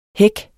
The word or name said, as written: hæk